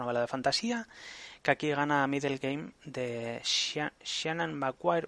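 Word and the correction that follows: Seanan: Bueno, en realidad es Shaunin McQuire, porque la Wikipedia te dice cómo tienes que pronunciarlo, pero yo no, no sé.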